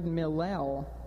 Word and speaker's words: millel: It's the word millel, M-I-L-L-E-L. It sounds like the word Hillel from where we get hallelujah.